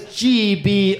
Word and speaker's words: Gborie: It's funny you're telling people that have only heard me call you David Gborie that the G is silent. Like they're like, what G? Where? What's going on? So his name is Gborie.